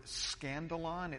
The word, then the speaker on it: skandalon: The word offense in the original language, in the original Greek language, is the Greek word skandalon. It's spelled with a K, S-K-A-N-D-A-L-O-N, skandalon.